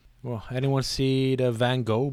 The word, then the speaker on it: Van Gogh: Van Gogh.